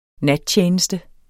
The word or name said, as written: nattjeneste